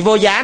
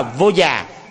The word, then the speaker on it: vô già: Chữ vô giá này đọc đúng đó là vô già, du già, pancavasika, có nghĩa là bình đẳng giải oan.